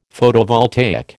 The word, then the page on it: Photovoltaic: American English